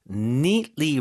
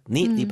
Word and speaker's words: neatly: It becomes egg김밥 with the egg neatly wrapping. 이게 이제 깔끔하게죠. neatly 발음 좀 도와주세요. – Neatly. – neat. 자, 거기서 좀 끊어주고 neatly.